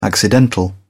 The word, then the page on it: accidental: British English